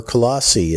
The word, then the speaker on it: Colossae: it needs to get to Colossae, or Colossae as it can be pronounced.